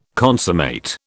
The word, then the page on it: consummate: British English